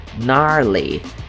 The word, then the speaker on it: gnarly: Então, ó, começa com G essa palavrinha. Gnarly. Gnarly. Não dá pra ouvir o G porque ele é mudo, mas beleza.